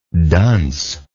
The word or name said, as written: duncishly